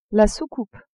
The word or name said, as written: soucoupe